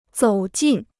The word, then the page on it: 走近: zǒu jìn